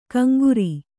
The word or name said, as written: ಕಂಗುರಿ